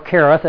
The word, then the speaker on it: Cherith: God said, go to the brook Cherith, and he was there, or Cherith as it would actually be pronounced.